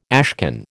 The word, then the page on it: ashcan: American English